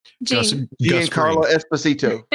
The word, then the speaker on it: Giancarlo Esposito: But didn't you say, that you told me a couple of days ago that Giancarlo Giancarlo Esposito.